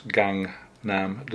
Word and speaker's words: Gangnam: I've been spelling it wrongly, I think, and pronouncing it wrongly. It's Gangnam District.